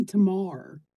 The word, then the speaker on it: tamar: Well, in Appalachia, I do hear, especially a lot of the older people, instead of saying tomorrow, they say tamar. – Okay. – So it would rhyme with guitar.